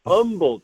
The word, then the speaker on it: Humble: It's not Humble. They will jump on you if you pronounce the H there. Yeah. Humble, Texas.